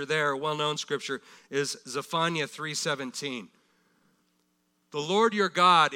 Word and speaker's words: Zephaniah: Now, the next one, number eight in our list of 12, we would pronounce it Zephaniah, okay? And from here on, probably most of us will continue to pronounce it that way. It's Zephaniah.